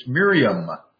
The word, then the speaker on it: Miriam: It's interesting, in the original, it's Miriam. As an M on the end, we gave her Hebrew pronunciation there.